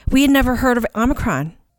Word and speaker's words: Omicron: Yeah, there's no extra N. – Omicron.